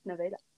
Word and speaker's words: Nevada: There's another city in Iowa called, a town in Iowa called Nevada, spelled like Nevada, but it's pronounced Nevada.